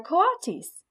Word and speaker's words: coatis: "These are coatis," Mrs. Brisbane said, reading from the letter, pronounced co-a-tis.